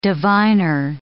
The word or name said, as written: diviner